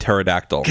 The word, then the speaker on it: pterodactyl: I thought it was petrodactyl. Pet pet. – It starts with a P, but you pronounce it pterodactyl.